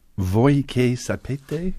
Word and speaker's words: Voi che sapete: Now, the aria is Voi che sapete? Is that it? Did I say it right? – You said it right.